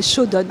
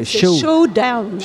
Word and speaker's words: Showdown: Je suis prof d'anglais, mais prononcé à la française, c'est Shodan. – Ah, c'est anglophone. Enfin, c'est Showdown.